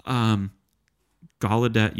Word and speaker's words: Gallaudet: Gallaudet University.